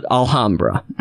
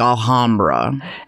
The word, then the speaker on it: Alhambra: You were like Alhambra. – And, but how do you pronounce it? – Alhambra. Because it's not a gorgeous city of Spain. It's not Alhambra,